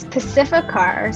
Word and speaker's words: Pacificar: P-A-C-I, you spell it because I'm totally going to butcher that. – It's okay. It's Pacificar. So it's P-A-C-I. F-I-C-A-R. It's like Pacific Ocean with A-R at the end.